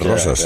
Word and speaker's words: Roses: Roses, le llaman Roses y no es Roses. En catalán es Rosas.